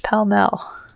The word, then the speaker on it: Pall Mall: 1950s cigarette commercial we found on YouTube confirms the brand is pronounced Pelmel.